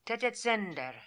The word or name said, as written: Tetetzender